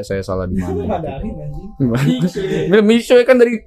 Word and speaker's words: Mixue: Lu lu pertama pas apa nemu tempat es krim lu bacanya gimana? – Mixue. Kayak gitu. Terus Felika. Felika. Dia jadi Felika. Bukan kayak gitu. Yang bener kayak gini. Gimana? – Mishwe.